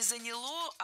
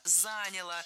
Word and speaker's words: заняло: Вот чтобы настроить карбюратор на хорошие пусковые качества для зимы, чтобы он ездил, то это заняло где-то пару дней работы на стенде. – Неплохо. – Нет, плохо, потому что не заняло, а заняло. Ударение не ставим на окончание ни в коем случае.